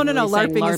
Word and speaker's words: LARPing: For those who don't know, LARPing, like L-A-R-P, stands for Live Action Role Play. – What was your LARPing character? And tell me if I'm saying that wrong. This is my first time. – No, LARPing is correct.